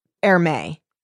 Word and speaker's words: Hermé: Or he's like, you could be, how do you say his name? Hermes? – That's what I thought. – Hermé? – Yeah, it's Hermé.